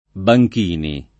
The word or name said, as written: Banchini